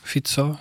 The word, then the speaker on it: Fico: Fico, wie spricht man es aus? – Fico, mhm.